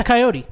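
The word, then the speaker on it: Kaiyote: Hiatus Cantaloupe. Hiatus Coyote.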